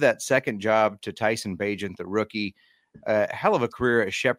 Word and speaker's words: Bagent: What do you think of the backup, Tyson Bagent? I can't pronounce it. Agent. Special Agent. Bagent.